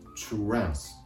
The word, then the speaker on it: translation: まず単語前半 trans、 trans。これは R の発音ですから発音する前に小さくうとような形で trans、 trans。